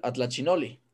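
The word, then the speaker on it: Atlachinolli: O peor aún, la representación del águila del Atlachinoli. ¿Sí lo dije bien? Por favor, corríjame. Sí, ¿verdad? – Sí, lo dijiste bien.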